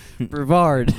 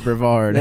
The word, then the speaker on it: Brevard: You're from Brevard. – Brevard. – Brevard, however you say that. – That's how they say it down there.